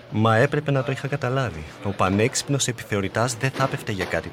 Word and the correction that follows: Επιθεωρητής: Επιθεωρητής. – Συγγνώμη. – Επιθεωρητής, όχι επιθεωρητάς.